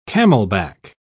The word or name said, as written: camelback